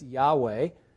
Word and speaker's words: Yahweh: which is pronounced today in a modern English as Yahweh.